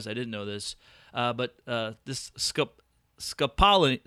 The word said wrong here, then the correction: scopolamine: It is scopolamine, is what I'm going.